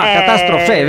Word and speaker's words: Catastrofe: Ah, Catastrofe, vedi. – C'è l'accento. – Catastrofe, certo. Nasce dall'etimologia di questa parola,